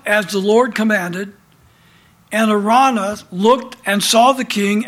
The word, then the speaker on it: Araunah: if you take the U out of the name and put in a W, it'll help you to pronunciate that word. A raw, like R-A-W, raw, Arana. So now you know how to pronounce that, okay?